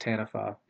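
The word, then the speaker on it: Taniwha: I heard that it's a Maori word and it's pronounced Tanafa, even though it's not spelled that way.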